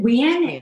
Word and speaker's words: Nguyen: We pronounce it Nguyen. It's like W-E-I-N.